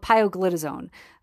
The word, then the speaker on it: pioglitazone: And that one is called pioglitazone.